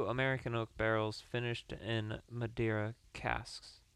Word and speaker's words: Madeira: Madeira finish. I hope that's how you pronounce it. – Good. – M-A-D-E-I-R-A. Madeira. Madeira. Madeira. We're going with Madeira.